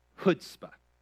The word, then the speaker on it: chutzpah: It's called chutzpah. Now, say that with me, and we're going to practice on three. Ready? One, two, three. Chutzpah. Chutzpah. Okay? The C is silent.